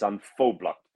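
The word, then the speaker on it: An Phoblacht: So I had to ring my mum and go, Mum, is it pronounced An Poblacht? And she went, it's An Poblacht.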